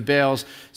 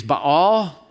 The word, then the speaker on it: Baal: Then the Israelites did evil in the eyes of the Lord and served the Baals. So you can pronounce this the proper way coming from the Greek is Baal. Coming from the Latin, we tend to say Baal. Either way is fine.